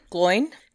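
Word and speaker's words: Glóin: Gloin. – Gloin. – Yeah. – Yes, exactly. He maintains... It's like glowing.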